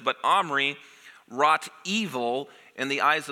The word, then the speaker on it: Omri: In verse 25, we learn of Omri, and Omri rhymes with Henri, and we all know what Henri people do, not good things.